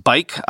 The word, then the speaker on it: Buyk: That's pronounced bike, I've learned, B U Y K.